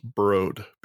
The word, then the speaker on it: Broad: It is spelled The Broad, but it is pronounced Broad because it's a dude's last name and that's how it's pronounced.